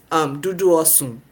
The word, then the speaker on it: dudu osun: The black soap, I mean, a lot of companies make it, kudos, but dudu osun. That is how you say it in my language.